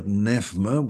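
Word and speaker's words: pneuma: You would have seen that word nephma, which is the Greek pronunciation.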